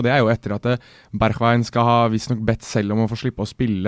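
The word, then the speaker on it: Berghuis: Det er uh, kraftige og heftige rykter nå om, som vi har googlet oss fram til, Steven Berchwein, som han uttales vel på nederlandsk.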